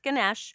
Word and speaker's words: Ganesha: And for those of you that aren't familiar with Ganesh, and it's pronounced Ganesh or Ganesha, so I'm getting distracted.